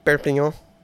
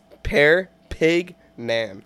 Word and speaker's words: Perpignan: Is that how you really say that? – That's how you say it. – What is it? – Perpignan. It's Perpignan.